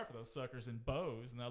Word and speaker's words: Boaz: We also have nearby, we have Boaz, which everybody assumes is Boaz. – It's Boaz, yes. It's spelled B-O-A-Z.